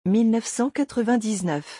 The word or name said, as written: 1999